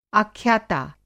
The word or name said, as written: आख्याता